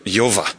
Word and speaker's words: Yahweh: It's pronounced Yova. Yova. That's how it's pronounced.